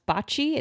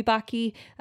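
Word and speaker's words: Bacci: I think her last name's would be pronounced bocce. It's B-A-C-C-I, but maybe it could be bocce because we're in America, so we make up our own pronunciations.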